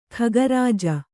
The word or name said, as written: ಖಗರಾಜ